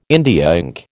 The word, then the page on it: India ink: American English